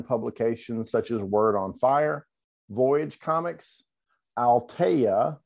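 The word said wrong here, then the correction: Aleteia: did I say that right? Alateia, and Busted Halo.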